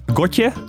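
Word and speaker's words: Gotye: Het is uh, Gotje. Volgens mij spreek je het zo uit. Gotje.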